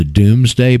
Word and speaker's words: Domesday: If you go look in Black's Law Dictionary under the Doomsday Book, it's spelled D-O-M-E-S, Domesday, but it's pronounced Doomsday.